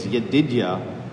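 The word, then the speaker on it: Yedidiah (ידידיה): Hebrew pronunciation is Yedidiah because of the Lord.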